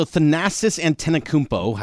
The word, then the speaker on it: Thanasis Antetokounmpo: All right, so Thanasis Antetokounmpo has been in Milwaukee for his entire career. – Excellent pronunciation, by the way.